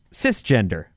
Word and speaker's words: cisgender: Cisgender is pronounced cisgender. It's pronounced cisgender.